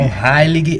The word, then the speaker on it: Heilig: Então, Heilig é a pronúncia correta.